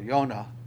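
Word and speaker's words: Yonah (יונה): In case you didn't know, Jonah means, or Yonah, it'd be the way it'd be pronounced without it being transliterated.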